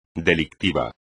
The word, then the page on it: delictivo: espagnol